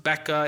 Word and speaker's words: Baca: It says, as they pass through the valley of Baca, or I've heard it pronounced Becca, it's a Hebrew word.